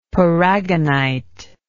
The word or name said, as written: paragonite